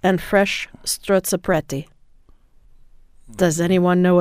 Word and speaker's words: strozzapreti: Any good Italian Catholic did I say it right? Yeah. Strozzapreti. I did apparently.